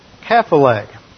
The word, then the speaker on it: kephale: Now the other key word that is listed here is the word translated head, which is the Greek word kephale. K-E-P-H-A-L-E. There's no C, soft C in Greek, and when this comes over into English, you have the word cephalic, which refers to the head.